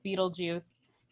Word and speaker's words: Beetlejuice: In the movie, they're played by Alec Baldwin and Gina Davis, who become ghosts haunting their former home, and an obnoxious, devious poltergeist named Beetlegeist, pronounced and occasionally spelled Beetlejuice.